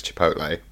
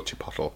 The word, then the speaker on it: Chipotle: Can we compare and contrast Taco Bell with the other American fast food institution that I've never been to, which is Chipotle, which I always used to call Chipotle. Apparently it's not, that's not how you pronounce it.